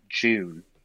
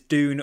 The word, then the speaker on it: Dune: My question, you know, with the UK-US divide, does anyone in the UK call it June? – Oh, this was doing a bit of the rounds on Twitter, wasn't it? How we pronounce is Dune or June, isn't it? – Yeah.